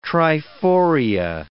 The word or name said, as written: triforia